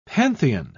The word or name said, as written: pantheon